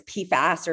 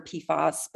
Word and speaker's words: PFAS: PFAS. – It's called, you can refer to it as PFAS or PFOS, but PFAS is probably what you're going to hear most people say, PFAS.